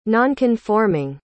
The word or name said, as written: nonconforming